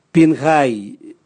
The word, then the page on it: bingai: Raga